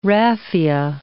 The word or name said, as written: raffia